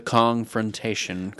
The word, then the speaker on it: Kongfrontation: The Kongfrontation, correct? – Well, yes, thank you.